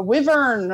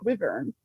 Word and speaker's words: wyvern: A wyvern. – Okay, that makes sense. See, I didn't know how to say it. – We needed Janine this episode. – Yeah, a wyvern.